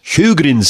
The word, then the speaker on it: Sjögren: Okay, so here's part of the problem is that Sjögren syndrome, and this is kind of a weird spelling, it's S-J-O with a little double dot over the O, so Sjögren's syndrome, it's like a Swedish word, the J is silent, and it's pronounced as an H.